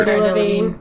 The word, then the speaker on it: Cara Delevingne: Cara Delevingne.